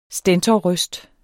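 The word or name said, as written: STENTORRØSTER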